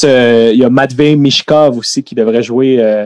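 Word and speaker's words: Michkov: J'étais sûrement mal prononcé encore une fois, mais je pense mm. qu'il pourrait jouer pour les Russes l'année prochaine. Non, Mishkov, c'est correct. Le H est avant le K.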